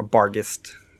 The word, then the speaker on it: Barghest: Now that you've seen him change form, Garrett, you know that this, you know Calkick is a Barg, try to pronounce this correctly, a Barghest, a Bargist, B-A-R-G-H-E-S-T, Barghest.